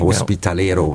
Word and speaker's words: Hospitalero: Sie sind das, was man ein Hospitalero nennt. Habe ich es richtig ausgesprochen? – Richtig, Hospitalero, ja.